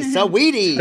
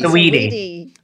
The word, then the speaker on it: Saweetie: I've been calling Shawty. I just didn't know how to pronounce Saweetie. Saweetie. – Saweetie. – Saweetie. Yes. I love the music. I love her, but I have been saying it wrong.